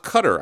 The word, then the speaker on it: Qatar: Jill writes in, it's pronounced Qatar, I believe. Okay, so not Qatar. We say Qatar. No, I'm sorry. Qatar. That's what she's saying. Qatar. We said Cutter last week because I had read somewhere that it's pronounced Cutter, but people are saying Qatar. Sepp Blatter said Qatar, so we don't know.